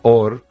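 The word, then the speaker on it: or (אור): Pero la luz, que se escribe, de hecho, en hebreo, se pronuncia or, es igual que ras, tiene el mismo número equivalente, que es el 207, que es el secreto.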